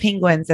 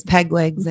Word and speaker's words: penguin: Yeah, He did a whole documentary and pronounced penguins as pegwigs, and penguin.